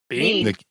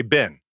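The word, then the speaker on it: been: Bean. Bean. Bean. – Yeah. So so Americans say bin. That's not a word. – No, I understand that, but that's how Americans pronounce it.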